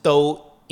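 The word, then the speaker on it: Do (道): Actually, that same character is pronounced Do in Cantonese and also in Japanese.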